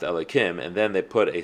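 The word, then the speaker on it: Elokim: Another thing also is that you'd be surprised that there are many people that don't know this, but there are times that Yud Kei Vav Kei is not pronounced with Aleph Dal Nun Yud. It's not pronounced Adai and then Noi. Rather, it's pronounced Elikim.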